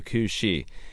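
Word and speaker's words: Cù Sìth: So there's a Scottish mythological hound called the Cú Chí, and it's spelled C-U-S-I-T-H.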